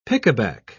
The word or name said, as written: pickaback